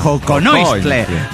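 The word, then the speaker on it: xoconostle: El acento en la segunda O. Joconoistle.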